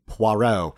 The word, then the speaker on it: Poirot: That's one of his sort of like bucket lists that Michael Winner is going to do a Poirot film and or Poirot. I can never say the name right. – Poirot.